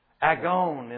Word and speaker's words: agon: Agon in the Greek, very simply spelled, is the root word. Pronounced agon. From that you get the verb agonizomai.